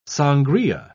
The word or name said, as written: sangria